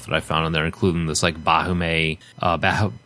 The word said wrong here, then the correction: Baphomet: I forgot how to say it, Baphomet, Baphomet, Baphomet uh, incense burner where the smoke comes out of his nose and uh, that sort of thing.